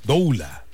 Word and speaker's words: doula: Dula, ¿no? No, es doula. Doula, sí se pronuncia.